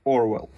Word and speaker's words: Orwell: Она называется Orwell, как Orwell, да, только по-английски. Orwell.